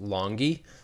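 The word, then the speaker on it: Langi: The only person I even recognize outside Quentin Williams is Harvey Lange, just because he was an undrafted player the Patriots picked up. – It's pronounced Longy.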